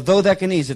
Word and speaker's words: Dodecanese: Patmos is located in the northwest end of the Dodecanese, or the Dodecanese, it's pronounced.